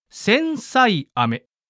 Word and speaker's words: 千歳飴: 千歳飴と書いて千歳飴と読むこの飴ですが、この名前には千歳まで長生きしてほしいという願いが込められており、形も命が長く続くことを意味する細長い形なのだそうです。